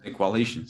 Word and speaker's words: The Coalition: lo digo bien, ¿no? The Coalition. The Coalition, sí.